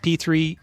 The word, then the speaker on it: P3: Next up is P3, and it is pronounced P3 in the show, but it's actually P cubed on the sign. But everybody calls it P3.